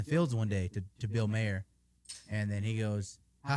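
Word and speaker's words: Maher: No, it's not Bill Meyer. – Bill Nye? – The science guy? – Bill. – Is it Bill Mayer? – Oh, that's how you say it.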